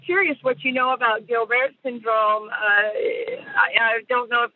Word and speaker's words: Gilbert: That's exactly. It's Gilbert. It's French. – Oh. – Gilbert.